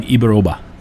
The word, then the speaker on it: Ibaroba: No, he did tell me it's like Ibaroba.